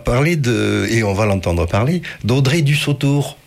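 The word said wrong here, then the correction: Dussutour: Du Sutour, j'ai mal prononcé,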